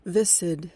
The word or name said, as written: viscid